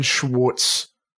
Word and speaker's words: Schwartz: Again, like I'm just really stoked to see Ben Schwartz.